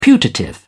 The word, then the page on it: putative: British English